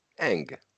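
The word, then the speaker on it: Ng: És majd még beszélgetünk az MLB történetének első női gm ről kinek nevét úgy ejtik, hogy? Kim G. Eng. Engnek ejtik?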